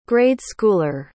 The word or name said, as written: grade-schooler